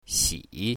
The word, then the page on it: xi3: mandarin